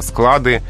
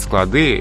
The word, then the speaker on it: склады: Вот в значении помещения для хранения здесь только склад, только склады, и во множественном числе ударение остается на корне, на окончании не переходит. И вот в этом случае сказать склады – это очень грубая ошибка.